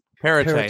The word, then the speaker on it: Perrottet: But I think the Premier, Dominic Perrottet, I can't ever pronounce his name correctly. – No one can pronounce his last name. – Ardeet, Ken, what was that? Perrottet, thank you.